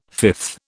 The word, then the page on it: fifth: British English